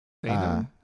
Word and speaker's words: seinen: Seinen? Is it S-E-I-N-A-N?